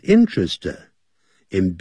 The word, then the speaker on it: interested: 可是他的爸爸要他当医生。But Paul is not interested in being a doctor at all。但是保罗却没有兴趣 is interested in，好，这个固定的搭配啊， is interested in，对什么有兴趣？ interested 不要念成 interested，这个是念太复杂太难， interested， interested 那个的把它停顿， interested 要这样念， interested ininterested in，我对音乐很有兴趣。